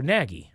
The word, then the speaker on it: Nagy: Rhymes with Shaggy. And a former NFL head coach. I can't give you any more. – Oh, Nagy.